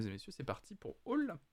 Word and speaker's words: Owlle: Je crois que ça se prononce bien Hall, c'est une artiste française de, de souvenir O-W-L-L-E.